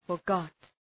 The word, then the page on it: forgot: {fər’gɒt}